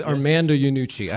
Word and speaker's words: Armando Iannucci: So the creator of Veep, Armando Iannucci, am I saying that right? – Yeah, Armando Iannucci.